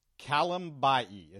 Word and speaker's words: Kalambayi: The final Texan sixth round pick in this draft, Stanford outside linebacker Peter Kalambayi, and I'm gonna screw that up, I'm sure, but it's Kalambayi is how they're pronouncing it. Kalambayi.